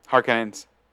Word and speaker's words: Harkonnens: Is you've got, like, the Galactic Emperor is working with the Harkonnens. – For some reasons, like, my brain goes, it has an H sound at the beginning and ends in horns. I'm like, no, it doesn't end in horns.